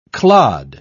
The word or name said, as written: clod